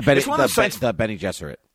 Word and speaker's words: Bene Gesserit: The Bene Grizzet. I don't know how to pronounce. – The Bene Gesserit. – Yeah, Bene Gesserit, yes.